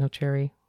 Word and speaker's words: nocere: Usually most people will say primum non nocere. Is that how you say it? Nocere? – Sure.